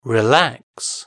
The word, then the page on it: relax: British English